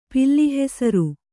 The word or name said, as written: ಪಿಲ್ಲಿಹೆಸರು